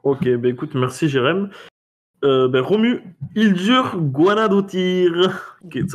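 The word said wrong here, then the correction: Hildur Guðnadóttir: Bon, enfin, moi, j'en ai un, mais c'est ma chronique, donc okay, t'as regardé comment ça se prononçait ? – J'ai écouté, surtout, parce que j'ai écouté le début d'une interview, et elle prononce son prénom et son nom, donc... C'est juste Hildur Gunnadóttir.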